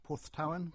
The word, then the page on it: Porthtowan: English